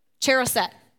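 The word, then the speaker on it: charoset: You have the, I don't know if I know how to say it, the Cheseret? Cheroset. Cheroset.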